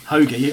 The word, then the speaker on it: Hoge: Hoagie. I was going to say, you're going to tell me that I've mispronounced his name. – Tom Hoagie, apparently.